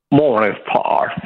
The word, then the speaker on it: Morningstar: Morningstar.